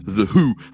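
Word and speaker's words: The Hu: Voici The Who. Hein, ça s'écrit H-U.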